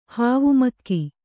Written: ಹಾವುಮೆಕ್ಕೆ

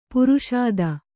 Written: ಪಾರ್ಷದ

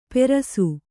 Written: ಪೆರಸು

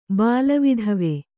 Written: ಬಾಲವಿಧವೆ

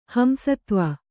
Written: ಹಂಸತ್ವ

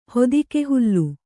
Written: ಹೊದಿಕೆಹುಲ್ಲು

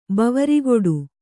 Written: ಬಾವರಿಗೊಡು

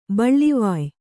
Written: ಬಳ್ಳಿವಾಯ್

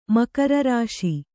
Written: ಮಕರರಾಶಿ

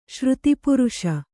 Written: ಶ್ರುತಿಪುರುಷ

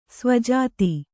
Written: ಸ್ವಜಾತಿ

